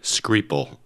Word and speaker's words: Skripal: Sergei Skripal. And the last name is spelled S-K-R-I-P-A-L. Sergei Skripal. – Sergei Skripal.